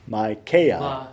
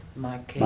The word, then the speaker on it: Micaiah: Micaiah. It's a long I and a long A. – Okay. – Well, it'd be like the last part is like Isaiah. – Isaiah. – So, Micaiah.